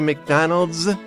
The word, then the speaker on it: McDonald's: 今日は食べ物のお話でしたが、日本でもおなじみマクドナルドさんは、なんと本場アメリカでは発音が違います。McDonald's というふうに言います。 – Right, McDonald's. – McDonald's. – Right, you can, if you say that, it sounds like you're really natural. Do you know how many McDonald's there are in Japan? – What, in Japan?